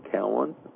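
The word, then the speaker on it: Cowin: So, but from now on, if you could write to me at, once again, it is Dr. Don Cowan or Global Redeeming Ministries.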